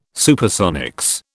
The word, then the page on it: Supersonics: British English